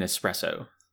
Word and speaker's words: espresso: Hey Jeff, why is there no X in espresso? – I think only heathens pronounce it with an X.